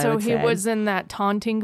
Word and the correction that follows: Taunton: Taunton, I think, is how it's Taunton? – Pronounced. T-A-U-N-T-O-N.